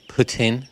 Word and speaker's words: poutine: Oh, here's a couple of poutine, and I'm not going to pronounce it poutine. Like people have pointed out, we pronounce it wrong. – Oh, yeah. – My secret, they're going to be at Actually 2021. The people who like to correct your pronunciation of poutine. – It's poutine. – Poutine.